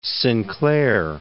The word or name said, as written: Sinclair